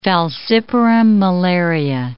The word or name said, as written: falciparum malaria